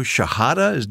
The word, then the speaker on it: Shahada: Thomason, I understand you call it Radio Shahada. Did I pronounce that correctly? – Right, sir.